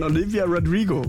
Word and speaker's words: Olivia Rodrigo: Beim nächsten Song, den wir euch jetzt spielen werden, von Olivia Rodrigo.